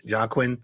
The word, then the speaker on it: Joaquin: How do you say his name? Joaquin? – Joaquin. – Yeah, Joaquin.